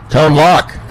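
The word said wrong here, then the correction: Tone Lōc: Are you going to Tone Lock? – Tone Loke. – Loke. Oh, we don't even know how to say it. Is that how you say it?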